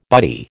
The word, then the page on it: buddy: American English